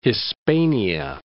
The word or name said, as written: Hispania